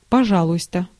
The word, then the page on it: пожалуйста: [pɐˈʐɑ.ɫʊ(j).stə]